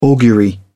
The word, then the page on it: augury: British English